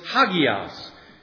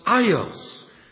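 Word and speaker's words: hagias (ἅγιος): And then John heard it in Greek, and the Greek word was hagias, and in modern Greek it would be pronounced ios.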